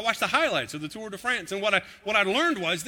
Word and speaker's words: Tour de France: And the Tour de France, that's how you say it when you're a real fan.